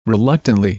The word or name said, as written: reluctantly